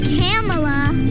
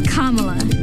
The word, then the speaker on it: Kamala: It's not Kamala. It's not Kamala. – It's not Kamala. – It's Kamala.